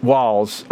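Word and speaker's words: Walz: No T. Just Waltz. Waltz.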